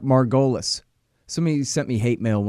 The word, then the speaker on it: Margolies: Spell it like Margolis. I called him Eric Margolis for a long time because I never asked him how to say his name.